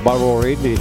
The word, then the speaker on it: Ballerini: Chelsea Ballerini, eccola qui, detta con pronuncia USA, Ballerini, ma comunque è italo-americana, Ballerini, eccetera eccetera.